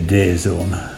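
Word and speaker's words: DAZN: DAZ? – Oh, sorry. I should say Day Zone.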